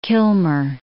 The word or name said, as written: Kilmer